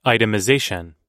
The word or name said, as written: itemization